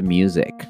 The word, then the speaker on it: music: Face the music. Face the music. Y una nota de pronunciación. No decimos music con S, sino con Z. Music. To